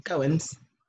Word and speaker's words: Goines: How do I say your last name, Janelle? Goins? – Yep, Goins.